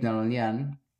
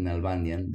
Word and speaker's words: Nalbandian: And then he went on to play David Nalbandian, or David Nalbandian, depends how you want to pronounce it, the American way or the Argentinian way.